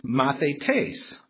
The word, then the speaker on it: mathetes: This time it's G3101, and it's pronounced mathetes, mathetes. And it's a noun that means a learner, a student, or a pupil.